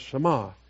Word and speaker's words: Shema: And my mother said, well, that's a Hebrew word. It's pronounced Shema.